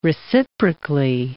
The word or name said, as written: reciprocally